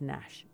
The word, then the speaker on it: Gnash: And it's like G Gnash, except he pronounces it just Gnash, just so you know.